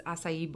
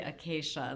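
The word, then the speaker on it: açaí: I am so impressed with the way you just pronounced acai. It's Did I get it right? – Years. Yeah, I still look at it and I say acacia and then my kids laugh at it.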